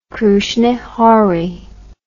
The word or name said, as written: Krusne Hory